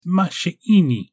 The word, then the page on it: ma shiini: Galindri